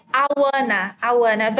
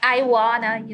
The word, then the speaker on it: Ayuana: Well, it's like Awana, but people always make jokes like Iwana, you know.